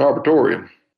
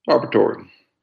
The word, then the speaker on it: arboretum: It's an arboretum. – Arboretum? – Yeah, that's how you say it. – It's spelled arboretum. – Arboretum.